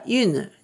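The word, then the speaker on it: une: で、女性系ならユヌになります。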